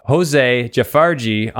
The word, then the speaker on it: Jose Jafferji: I have Jose Jafferji on the show, and I think I did say that right.